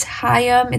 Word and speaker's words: Haim: That's how you say that band's name. It's Haim. It's like two syllables.